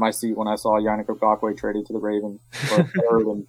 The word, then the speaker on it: Yannick Ngakoue: How do you say his name? – Yannick Ngakoue. – Ngakoue. Yannick Ngakoue.